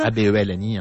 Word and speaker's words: Abelny: Abelny, hein, comme ça se prononce, ou bien sur le Facebook, le Insta, le Twitter, tout ce que vous voulez, de l'agence matrimoniale musulmane Abelny.